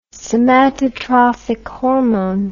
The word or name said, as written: somatotrophic hormone